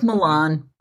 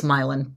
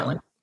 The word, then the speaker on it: Milan: It looks mm-hmm. like Milan, but it's Milan, pronounced yeah. Milan.